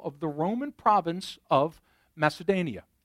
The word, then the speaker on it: Macedonia: I might have mispronounced that word. I was close. Macedonia. I knew that didn't sound right to my ear when I said that.